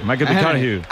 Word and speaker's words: McConaughey: Hay que ir corrigiendo la pronunciación. McConaughey.